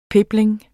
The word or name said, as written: pebling